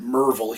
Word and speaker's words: Maryville: They pronounce it Merville here.